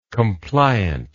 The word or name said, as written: compliantly